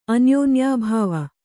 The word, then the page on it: ಅನ್ಯೋನ್ಯಾಭಾವ: anyōnyābhāva